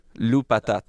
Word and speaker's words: Lou Patat: Lou Patat.